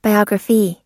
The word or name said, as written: biographee